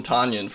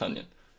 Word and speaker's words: Tonyan: Everyone called him Tanyan for the longest time, but he came out and was like, no, it's pronounced Tunyon.